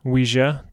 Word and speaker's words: Ouija: How is it pronounced Ouija board? – Oui, like French. – Yeah. – And then I think we're supposed to be saying ja. – Ouija, the Ouija, Ouija board.